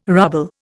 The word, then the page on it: ruble: Indian